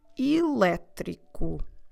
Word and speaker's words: elétrico: The word for tram it's actually like the word we have for electric because the word is o elétrico. O elétrico. Como se escreve elétrico? E, L, E, com acento, T, R, I, C, O.